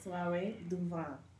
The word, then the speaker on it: Soiree Du Vent: Yeah, we've been saying it wrong because we Americanized everything. – We are sheltered Americans. We don't know anything, but it is the proper enunciation is Soiree Du Vent.